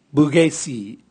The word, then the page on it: bugesi: Raga